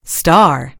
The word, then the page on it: star: American English